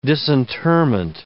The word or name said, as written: disinterment